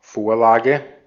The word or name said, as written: template